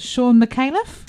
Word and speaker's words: Sean McAuliffe: Now, I pronounced his name wrong last time, but Sean McAuliffe. – Yes, that's right.